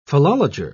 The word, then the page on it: philologer: British English